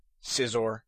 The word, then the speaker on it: Scizor: Same thing with Scizor, the evolution of Scyther. And also, Scyther is also mispronounced. I've heard people saying Scizor and Scyther. Again, the C is silent. So Sceptile, Scizor, and Scyther.